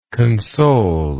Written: consolingly

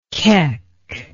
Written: keck

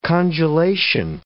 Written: congelation

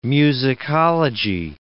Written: musicology

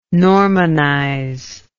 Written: normanize

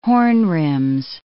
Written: horn-rims

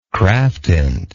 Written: craftint

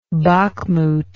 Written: bakhmut

